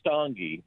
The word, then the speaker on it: Stange: John, you pronounce your last name Stange? – It's pronounced Stange,